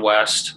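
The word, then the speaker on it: Wiest: You can just, you know, John West, my last name spelled so funky. I don't know why my ancestors, it's W-I-E-S-T. The I is silent, but probably Facebook.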